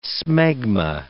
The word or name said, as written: smegma